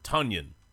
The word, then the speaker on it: Tonyan: And apparently his name rhymes with Funyan. So, Robert Tonyan. Think Funyan.